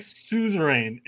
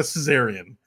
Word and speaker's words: suzerain: It's like suzerain. S-U-Z. It's one of their fancy words. R-A-I-N. Suzerain. A sovereign or state having some control over another state that is internally autonomous. – That's it. That's the word I meant. – Suzerain or suzerain. So it's not a Caesarian.